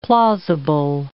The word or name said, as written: plausible